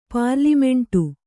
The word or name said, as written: ಪಾರ್ಲಿಮೆಂಟು